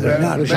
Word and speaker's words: Bernhardt: Tienes que pronunciar a la francesa, Bernard.